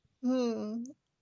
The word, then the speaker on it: Wren: Or the W is silent? – Both. – Wren? – Yes, you aced that quiz.